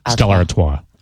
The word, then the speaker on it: Stella Artois: Stella Artois.